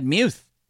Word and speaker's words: Muth: I think he said Muth. I realize that doesn't go with the chant, but I think that is the actual pronunciation.